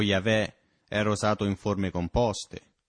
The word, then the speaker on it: Yahweh: Ci sono quelli che dicono Geova e quelli che dicono Yahweh. Tutto quello che abbiamo sono queste consonanti, YHWH. Loro non scrivevano le vocali, e così non sappiamo quale sia la vera pronuncia. Ma la maggior parte degli studiosi ritiene che sia Yahweh. Ma è la parola ebraica colui che diventa.